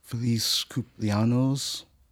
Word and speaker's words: Feliz cumpleaños: Feliz cumpleaños.